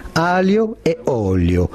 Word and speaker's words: aglio e olio: And the traditional way of pronouncing that dish is from our friend Julien. – Right. – Olio, aglio e olio, spaghetti, aglio e olio.